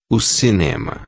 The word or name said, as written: cinema